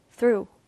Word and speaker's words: through: 자, 두 번째 이 단어에서는 th의 사운드 있기 때문에 through 라고 th라는 소리만 나야 합니다.